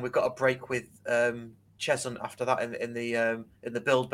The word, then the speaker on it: Cheshunt: Oh, you have done your research, because I've been waiting for you to pronounce it Cheshunt, and I was going to jump all over you, mate. – Chestnut. I thought Chestnut was the first one.